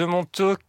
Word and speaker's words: Montauk: Par exemple, le monstre de Montauk. Je ne sais pas si je prononce bien. – C'est quoi ? Oui, le monstre de Montauk.